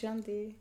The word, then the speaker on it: shanti: But what do you call it, shanti in Indian? It's pronounced shanti.